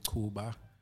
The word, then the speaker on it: Cuba: Uh, so, formally, my name is Ayanda, surname Kuba. A great one, I should say. It's spelled C U B A, like the small Cuban islands.